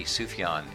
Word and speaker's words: Sufjan: Sufjan.